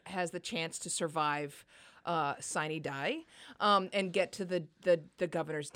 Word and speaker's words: sine die: Now, sine die is how people in Georgia pronounce it. It comes from Latin, and if you were to pronounce it according to Latin guidance, it would be sine die.